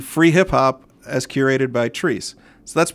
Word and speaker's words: Chreece: It happens in Fountain Square every summer, and it's spelled C-H-R-E-E-C-E. but it's pronounced Trees.